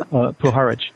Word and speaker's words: Puharich: You're talking about Pluharic. I'm not sure how you say his name. – Puharich, right.